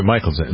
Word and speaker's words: Mickelson: Is it Mickelson? – Mickelson. – Mickelson, right.